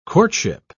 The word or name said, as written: courtship